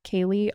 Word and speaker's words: Kaely: It's at Kaely RD. My name is spelled kind of weird. It's K-A-E-L-Y.